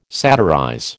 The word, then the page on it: satirize: American English